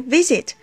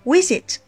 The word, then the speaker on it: visit: 比如 visit，很多人会念成 visit。